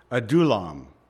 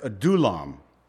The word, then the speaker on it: Adullam: That's how you say it. I've heard the Hebrew words butchered so much that I'm really trying to come up with the correct pronunciation, so I'm hoping that I've got it right. I've looked at a number of references, and tonight we have some pretty different names here, but that's Adullam.